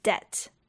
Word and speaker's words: debt: 那说到这些不发音的字母，最常见的就像是 debt，负债，d e b t，可是那个 b 是不发音的。